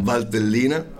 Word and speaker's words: Valtellina: You got to just say that region again. I can't pronounce it. – In Valtellina.